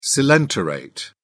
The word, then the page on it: coelenterate: British English